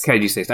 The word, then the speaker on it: Caduceus: So I've always pronounced it Caduceus. So is it Caduceus? – Caduceus. I put the syllables in.